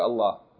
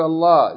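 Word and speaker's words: الله: So that's the rule that we discussed previously, and we just mentioned it now, just to remind us that the rule is, whenever the word Allah is preceded by kasra, it's pronounced light. Okay? Bismillah. Tarqiq. But if it's preceded by dhamma or fatta, then it's pronounced heavy.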